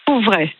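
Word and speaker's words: Coupvray: Je crois qu'on dit Coupevray. – Coupevray, vous me dites. – Sans le P.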